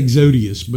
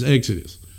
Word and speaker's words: Exodus: I always want to say Exodius, but it's Exodus.